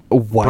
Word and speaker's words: Weiss: Now, it is pronounced Weiss, right? – Weiss. – Berliner Weiss. – Really? No, if it's German, a W, it's pronounced as a V.